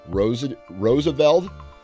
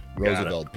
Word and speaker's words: Rozeveld: And today, all the way from Michigan, we have Nate Rozeveld. Did I say that right? – Yes. – Perfect. – Yep, Rozeveld. – Rozeveld.